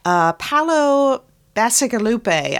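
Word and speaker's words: Paolo Bacigalupi: In Paolo Bassigalupe, I think is how the author's name is pronounced.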